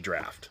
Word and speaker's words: draught: It's spelled D-R-A-U-G-H-T, but it's draught. It's, I don't know if it's Gaelic or whatever, or... But... If you see what looks appears to be drought, it's really draft.